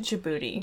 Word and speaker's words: Djibouti: I think you say it Djibouti.